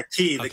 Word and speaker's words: Qi: Is that how you say it? Qi? – Yeah, Qi, kitchen.